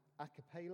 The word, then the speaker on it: agape: And like I was talking to Steve Dawson, and he was saying that love is called agape love. Is that right? Is that how you pronounce it?